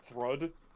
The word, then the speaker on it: Thrud: I want to point out, by the way, it's pronounced Thrud. – Oh, Thrud, because the first chapter I saw with her is like Pruder. I'm like, yes, but like that's how it looks in English, but like it's actually said as Thrud.